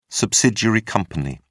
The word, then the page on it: subsidiary company: British English